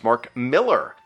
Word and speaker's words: Millar: Paul says, Mark Millar is pronounced Mark Miller, but I did enjoy your brazen certainty as you told everyone something completely and utterly wrong.